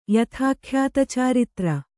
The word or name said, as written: ಯಥಾಖ್ಯಾತಚಾರಿತ್ರ